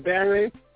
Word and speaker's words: Baron: My name is Baron, Rocker for Jesus Mac. And you can just like Baron Mac McLean on Facebook, and I'm also on Twitter, Baron Mac, and it's spelled B-A-R-O-N-M-A-K. Again, that's B-A-R-O-N-M-A-K.